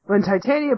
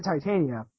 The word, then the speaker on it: Titania: I pronounce it Titania. He pronounces it Titania. Titania is correct if you're going with like the Eastern pronunciation of things, like Urza Titania from Fairy Tale. – I go to Western Titania because I see Titan, I see IA after that, so it's Titania for me.